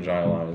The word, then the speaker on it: Jai Alai: Yeah, Cigar City is the brewery, and then Jai Alai is the...